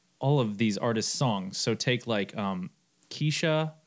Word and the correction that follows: Kesha: Kesha, God.